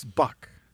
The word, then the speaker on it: Buck: It's Buck. Rhymes with a curse word.